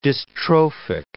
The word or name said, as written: dystrophic